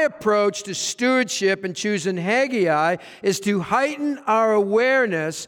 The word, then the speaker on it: Haggai: And we see this with the people of Judah in the time of Haggai. And so I invite you to turn your Bibles to the short book of Haggai, or you can pronounce it Haggai.